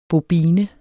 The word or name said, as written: BOBINERNE